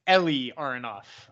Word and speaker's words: Eli Aronov: Ely Aronov versus Zachary Reese. So we got a Israeli versus the American, correct? – Yeah, and it's Eli Aronov, is how you say his name. – Eli, excuse me.